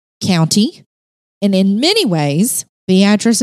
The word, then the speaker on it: Beatrice: And no, I'm not mispronouncing it. We would call this Beatrice here in the state, the great state of Alabama. But in Nebraska, they say Beatrice.